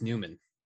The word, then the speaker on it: Nyman: One that I missed on was Kingston Frontenac's player played with Sam Bennett and Lawson Krause on that line was Linus Newman. Spelled N-Y-M-A-N, but pronounced Newman.